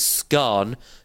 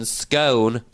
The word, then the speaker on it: scone: The correct pronunciation of scone, of scone, is scone to rhyme with gone rather than scone to rhyme with bone.